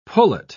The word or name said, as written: pullet